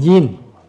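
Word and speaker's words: yin (音): Âm á, là yin. Yin là âm nè.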